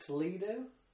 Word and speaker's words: Toledo: Is it Toledo? – Yes.